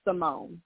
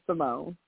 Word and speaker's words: Simone: Everyone wants to say it because that's how it's spelled. But, no, it's pronounced Simone. It's just Simone.